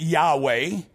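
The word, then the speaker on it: Yahweh (יהוה): But in Hebrew, there are no vowels. And so we pronounce it Yahweh just so we have something to say.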